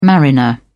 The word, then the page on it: mariner: British English